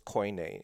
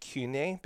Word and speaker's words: koine: It is very, it's commonly taught in seminary that the Greek in the New Testament is what's known as koine. Some people pronounce it kune because of the definitions and how people have the work in translation and pronunciation.